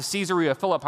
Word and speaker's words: Caesarea Philippi: I want you to notice that Jesus intentionally takes the disciples to Caesarea Philippi. And you can pronounce it that way because it's partly named after Caesar, uh, Caesar Augustus, as well as Philip the Tetrarch.